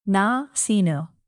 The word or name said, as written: nasinu